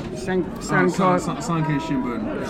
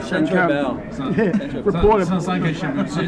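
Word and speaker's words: Sankei Shimbun: Sankai, is that how you pronounce that? – Sankai Shimbun. Sankai Shimbun.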